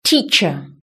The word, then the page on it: teacher: British English